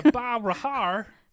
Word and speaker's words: Roghar: Part two, the three monkeys of Roghar. Roghar? Roghar. – Roghar. – Roghar. – Roghar. The G is silent.